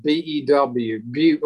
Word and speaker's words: Bew: Yeah, B E W, like a few with a B, Bew.